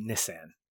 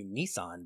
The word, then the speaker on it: Nissan: You can call me Nissan for this episode, if you'd like. – I will call you Nissan because we are in America, and that is how it is pronounced.